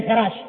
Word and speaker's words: حراش: من قال خراش يعني بالخاء بدل الحاء، في وارد ربعي، ربعي بن حراش هو بالحاء المهملة وليس بالخاء المعجمة، وليس بالخاء المعجمة.